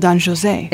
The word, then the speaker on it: Don José: You asked why I mispronounced Don Jose. It's into a cultural thing here. Bizet is French. And the opera is in French. – And the opera is in French, not in Spanish. – That's why he's called Don José.